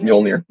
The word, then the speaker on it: Mjolnir: Mjolnir. – Mjolnir.